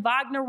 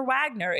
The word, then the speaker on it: Wagner: It's like it's like Wagner Wagner. It's a V. It's not a W.